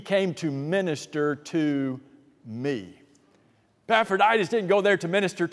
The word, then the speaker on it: Epaphroditus: You don't have to know how to spell that in order to get to heaven, but you do need to know how to pronounce it, so learn it. It's Epaphroditus. Anyway, so Epaphroditus in Philippians 2 went on a mission trip.